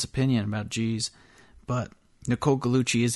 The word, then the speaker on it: Gugliucci: Now, her name looks like it's pronounced Nicole Gugliucci because she's got that silent G, and that silent G is rare.